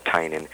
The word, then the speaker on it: Tynan: It's spelled T-Y-N-A-N, and it's pronounced Tynan.